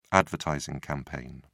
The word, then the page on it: advertising campaign: British English